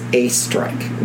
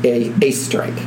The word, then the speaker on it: Oesterreich: And her husband's name was Fred, and their last name was A-Strike. Now, it's not spelled this way. That's how it's pronounced. It's spelled O-E-S-T-E-R-R-E-I-C-H. – Well, that's horrible. – But it's pronounced A-Strike. It's German.